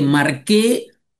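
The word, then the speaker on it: Marche: Marqué, que se escribe Marché,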